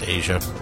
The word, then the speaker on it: Aja: Asia, I think it's actually pronounced Asia. A-J-A, Asia, yeah.